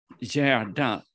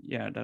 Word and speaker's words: Gerda: Gerda Lindel. I can send the name. It's Gerda, it's spelled G-E-R-D-A, Gerda or Gerda. – G-E-R-D-A, Gerda, okay.